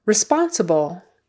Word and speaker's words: responsible: Okay, responsible. Responsible is how we usually pronounce it in casual conversation. Responsible. Could probably get away with that. Responsible. The accent is on the second syllable.